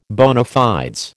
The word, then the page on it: bona fides: American English